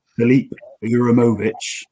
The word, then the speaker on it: Uremovic: Filip Uremovic, if I've pronounced his name correctly, we hope. James, we'll start with you. What were your first thoughts when you saw the deal announced yesterday? – I was really pleased, actually. Excellent pronunciation there, I think.